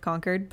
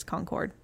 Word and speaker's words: Concord: As we would say it up north, Concord, but how they say it down here is Concord.